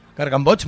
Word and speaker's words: Gargamboig: Una banda que se llama Gargamboch, ¿se pronuncia? – Gargamboch, muy bien.